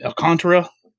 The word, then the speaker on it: Alcantara: You know, because if I'm going to mispronounce his name, Alcantara, is that right? – Yeah.